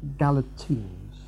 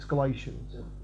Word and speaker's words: Galatians: Okay, tonight, Galatians. No, it's not how you say it. It's Galatians.